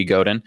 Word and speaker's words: Godin: So for me, the best advice I've ever been given, actually for any role pretty much, is there's a book by Seth Godin, Hard G Godin.